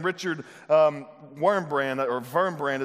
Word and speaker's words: Wurmbrand: One of the most amazing men in our history in the 20th century, in 1945, there was a man named Richard Wurmbrand is how you say it.